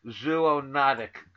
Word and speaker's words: zoonotic: And uh, uh, I, have been standing in front of the mirror for the last 24 hours trying to pronounce zoonotic correctly. Did I get it? Am I close? – Yep, yep.